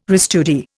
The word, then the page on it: restudy: Indian